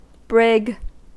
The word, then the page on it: brig: American English